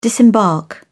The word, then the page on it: disembark: British English